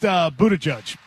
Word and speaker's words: Buttigieg: And it's pronounced Buttigieg.